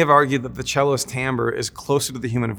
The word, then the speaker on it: timbre: There are some who describe, there's a word in music, which is timbre, which is spelled timber, sort of, but it's timbre, trust me, T-I-M-B-R-E.